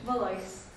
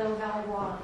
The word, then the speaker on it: Valois: Valois. – Okay. – The Valois is the correct French. – All right. – Hi, my name is Dawn Valois.